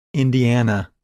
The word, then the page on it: Indiana: English